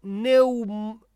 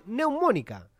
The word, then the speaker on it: neumónica: Estados Unidos llama a todos sus ciudadanos a dejar Rusia por posible brote de peste neumónica. No sé si lo dije bien. Neumónica. Sí, porque acento en la O. Neumónica. Esdrújula.